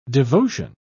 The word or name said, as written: devotion